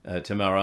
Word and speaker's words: Tamara: And I'm sorry that it has such fallout for your family, Tamara.